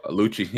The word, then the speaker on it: Lucci: I saw they tried to get, I don't know how to say his name, Lucky, L-U-C-C. – Oh, Lucci, yeah.